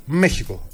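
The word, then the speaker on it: México: Άκου, το Μέχικο είναι Μέχικο ή Μεξικό, μου το είπαν τώρα στην Αμερική. – Για πες. – Αναλόγως αν, είσαι Μεξικάνος ή είσαι Ισπανός. Έτσι μου είπαν. Το προφέρεις Μέχικο.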